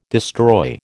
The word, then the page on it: destroy: American English